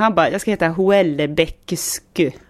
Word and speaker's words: Houellebecq: Det stavas alltså Houellebecq. Och så uttalas det Houellebecq ungefär som att det stavas så här Houellebecq.